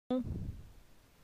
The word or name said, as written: Gon